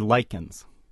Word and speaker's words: lichen: It's spelled L-I-C-H-E-N, but it's pronounced lichen.